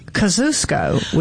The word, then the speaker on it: Kosciuszko: And they say it Kosciuszko, which is... probably the correct way to say it. But that's not how we say it in Mississippi.